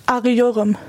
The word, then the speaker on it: Arıyorum: Wie spricht man das auf Türkisch richtig aus, das Wort? – Arıyorum.